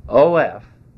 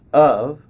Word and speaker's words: of: OF, we pronounce of, and somehow we change the F to a V.